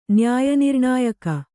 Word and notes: ನ್ಯಾಯನಿರ್ಣಾಯಕ: nyāya nirṇāyaka

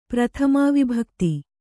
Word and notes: ಪ್ರಥಮಾವಿಭಕ್ತಿ: kannada

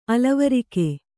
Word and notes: ಅಲವರಿಕೆ: alavarike

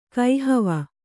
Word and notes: ಕಹ್ವ: kahva

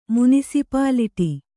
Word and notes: ಮುನಿಸಿಪಾಲಿಟಿ: munisipāliṭi

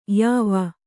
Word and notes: ಯಾವ: Kannada

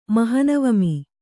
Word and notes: ಮಹನವಮಿ: mahanavami

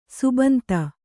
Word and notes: ಸಿಬ್ಬಂದಿ: sibbandi

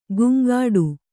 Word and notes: ಗುಂಗಾಡು: guŋgāḍu

